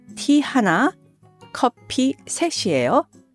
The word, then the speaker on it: 셋이에요: Now, please note that 셋, 3, 이에요, the be verb, is combined and is pronounced 셋이에요. Separately, it's 셋 followed by the verb 이에요. Putting these together, they're pronounced 셋이에요.